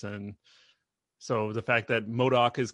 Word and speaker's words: MODOK: Did I say that right? MODOK? – You did, MODOK.